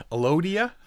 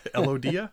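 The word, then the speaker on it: Elodea: Plants, Brazilian Elodia. It's a water weed. – I was wondering how that was going to be pronounced. – Elodia. Elodia.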